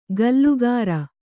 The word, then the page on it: ಗಲ್ಲುಗಾರ: gallugāra